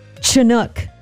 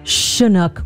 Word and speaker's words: Chinook: I would like to formally apologize for pronouncing Chinook with a CH instead of an SH sound.